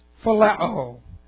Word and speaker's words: phileo: This is phileo. Let's take a look. Phileo. P-H-I-L-E, long O. Phileo.